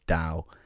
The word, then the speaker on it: Tao: It's spelt Tao, said Tao. I didn't say Tao until a few days ago until someone corrected me.